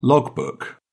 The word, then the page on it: log book: British English